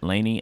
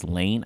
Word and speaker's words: Layne: I mispronounced her name. I said Laney. It is Layne.